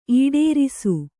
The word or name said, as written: ಈಡೇರಿಸು